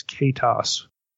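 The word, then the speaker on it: ketos: In transliteration, that's K long E T O S, Katos, which loosely would refer to a sea creature.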